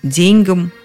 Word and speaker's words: деньгам: И орфоэпический словарь под редакцией Аванесова, и словарь ударения Агеенко и Зарвы подтверждают – деньгам это вариант устаревший, несовременный.